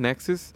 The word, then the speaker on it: NXIVM: Nexus. – And it's spelled NXIVM.